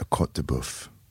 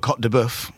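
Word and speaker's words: côte de bœuf: One of my favorite pieces of meat is a cote de boeuf. Did I say that right? Cote de boeuf. Yeah, you did.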